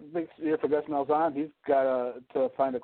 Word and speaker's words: Malzahn: Malzahn. – Yeah. – Malzahn.